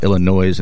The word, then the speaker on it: Illinois: They was like, it's Illinois. The S is silent.